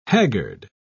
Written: haggard

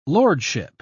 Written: lordship

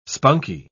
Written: spunky

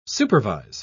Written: supervise

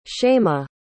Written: shaimaa

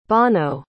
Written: bono

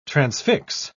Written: transfix

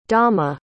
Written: dama

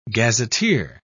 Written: gazetteer

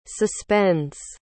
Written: suspends

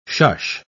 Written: shush

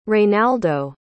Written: reynaldo

